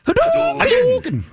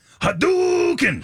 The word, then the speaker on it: Hadouken: But you have to say it like that. – Yes. – Yeah. – Wait, say it again. – Hadouken. – Hadouken. – Ready? – Hadouken! – There you go.